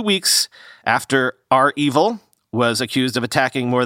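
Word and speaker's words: REvil: Remember R Evil? That big ransomware gang, by the way, I've learned it's pronounced R-Evil, not Reevil, as I was saying.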